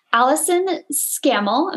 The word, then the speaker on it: Allyson Scammell: I am super excited today because we have a special guest, Allyson Scammell.